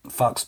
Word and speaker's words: Fux: Yeah, Fox Biz. It's not rude. It's spelled F-U-X.